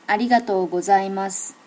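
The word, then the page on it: arigatogozaimasu: native Japanese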